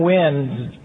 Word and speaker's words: Nguyen: Now, Tevin Wynn's last name is not spelled W-I-N. It's spelled N-G-U-Y-E-N.